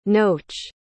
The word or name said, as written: noach